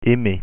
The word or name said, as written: aimer